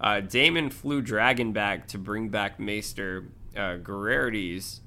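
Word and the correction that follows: Gerardys: I think that's how you say his name. – Gerardus. – Gerardus.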